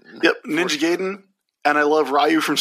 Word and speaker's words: Gaiden: Gaiden, or Gaiden, I guess, is probably how that is properly pronounced.